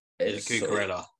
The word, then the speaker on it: Cucurella: And I've seen, obviously, the Brighton, I'm probably going to butcher his name, Cucurella. – Cucurella, I think it's right.